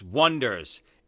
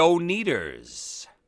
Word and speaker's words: Oneders: There's just so many funny scenes in the movie where, you know, they call themselves the Wonders, and they spell it O-N-E-D-E-R-S, Wonders. And, you know, they get announced as, and here they are, the O-Needers.